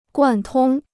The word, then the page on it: 贯通: guàn tōng